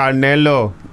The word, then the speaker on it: Canelo: So Canelo. You have to say it like that.